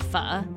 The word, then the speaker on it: pho: Places with names like Pho Shizzle, Good Pho You, Forever Yum, Forever, and this one's really painful, 9021 Pho. It's actually pronounced pho, by the way.